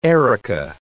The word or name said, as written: erica